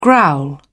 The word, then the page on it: growl: British English